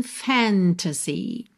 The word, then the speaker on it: fantasy: Normalmente, a palavra fantasy tem a sílaba tônica na primeira sílaba. Fantasy, fantasy. Aqui na música, como essa melodia se estica um pouquinho mais, a gente vai ter fantasy. Então, essa última sílaba acaba ficando um pouquinho mais alongada.